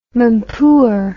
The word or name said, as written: mampoer